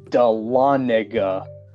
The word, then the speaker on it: Dahlonega: and he pronounced it the Dahlonega. That is not how it's pronounced. It is pronounced Dahlonega.